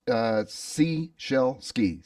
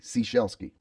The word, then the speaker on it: Ciesielski: It's very close. Ciesielski. Ciesielski.